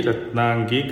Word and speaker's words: Ratnangi: Kanakangi, Ratnangi,